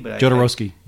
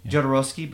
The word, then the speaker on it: Jodorowsky: Jodorowsky. – Jodorowsky, but I sure know about his damn documentary.